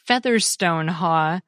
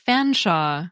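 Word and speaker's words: Featherstonehaugh: The surname that looks like Featherstone Haw can be pronounced to sound like Fanshawe, for example, while the place that looks like Torpenhow in Cumbria is pronounced Trepena.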